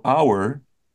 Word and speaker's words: hour: So, if it sounds like a vowel, we use un, and so our is a silent H, so we say an hour.